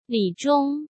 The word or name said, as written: Li Zhong